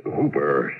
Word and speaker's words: Houper: The name's Hooper, spelled H-O-U-P-E-R, but pronounced a rhyme with Cooper.